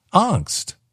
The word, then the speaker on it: angst: How do you say A-N-G-S-T? A-N-G-S-T. And I said, angst. And he said, it's not angst. It's angst. It's a hard A or angst.